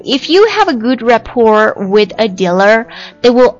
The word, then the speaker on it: rapport: Rapport 注意一下啊我第一次读的时候我把这个 T 加上去那这个单词它的读音是没有特音的它读作 Rapport R-A-P-P-O-R-T